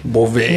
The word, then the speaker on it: Beauvais: Is it Bova or Bouvier? – It's Beauvais. It has a French.